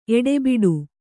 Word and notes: ಎಡೆಬಿಡು: eḍebiḍu

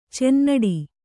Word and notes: ಚುನ್ನಾಟ: cunnāṭa

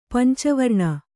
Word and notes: ಪಂಚವರ್ಣ: panca varṇa

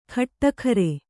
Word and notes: ಖಟ್ಟಖರೆ: Kannada

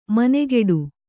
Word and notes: ಮನೆಗೆಡು: manegeḍu

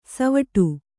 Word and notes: ಸೀವಟ್ಟ: Kannada

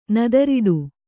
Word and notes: ನೆದರೊಡೆ: nedaroḍe

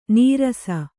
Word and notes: ನೀರಸ: Kannada

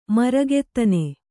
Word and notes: ಮೃಗಾದನ: mřgādana

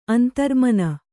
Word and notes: ಅಂತರ್ಮನ: kannada

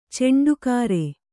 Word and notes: ಚಂಡಕರ: Kannada

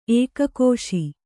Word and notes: ಏಕಕೋಶ: ēkakōśa

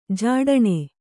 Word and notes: ಝಟ್ಟನೆ: Kannada

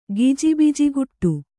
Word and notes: ಗಿಜಿಬಿಜಿಗುಟ್ಟು: Kannada

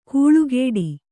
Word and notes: ಕೂಳುಗೇಡಿ: kūḷugēḍi; kannada

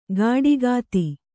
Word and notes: ಗಾಡಿಕಾತಿ: gāḍikāti

